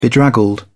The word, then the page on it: bedraggled: British English